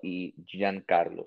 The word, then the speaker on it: Giancarlo: So OLE Giancarlo.